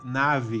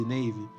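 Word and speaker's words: Navi: Inclusive, muitos conhecem ela também como Nave, porque se escreve Nave o nome dela. Mas eu acho tão engraçado quando o povo fala Nave em vez de Navy.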